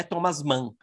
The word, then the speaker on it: Thomas Mann: Ele integra a companhia Ganimedes, eh, Ganimedes de Teatro, para a qual adaptou a novela Mário Mágico, de Thomas Mann, acho que é assim que se pronuncia, né? Thomas Mann, é, para o espetáculo O Mágico, que ele também co-dirigiu e protagonizou.